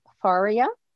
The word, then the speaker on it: Faria: Raven, Rabbit, and Deer is also a Pajama Press book, and it's by Sue Farrell Haller, illustrated by Jennifer Faria. I shouldn't know how to say that because it rhymes with Maria. Well, it doesn't rhyme, but it's spelled the same way.